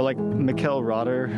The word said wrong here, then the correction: Rother: I don't know how you actually pronounce his name. – Mikkel Rotter? – Like Michael Rotter, huh? – Yeah. Rother. – Michael Rother.